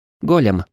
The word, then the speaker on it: Голем: Голем правильно произносить с ударением на первый слог.